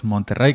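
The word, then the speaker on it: Monterey: llegó también MacOS Monterrey, que aunque se escribe em, con una R, se pronuncia como si tuviera dos, MacOS Monterrey,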